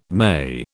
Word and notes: May: British English

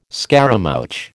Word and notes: scaramouch: American English

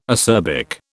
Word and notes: acerbic: British English

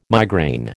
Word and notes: migraine: American English

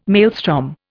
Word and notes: maelstrom: Indian

